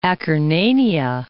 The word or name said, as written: Acarnania